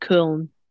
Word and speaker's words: Köln: In Köln, Mülheim, which is a suburb of Cologne. If you know that, but they pronounce it Köln.